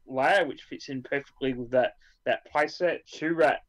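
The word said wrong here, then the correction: Chirrut Îmwe: Is that Chirrutimoy? – Yes.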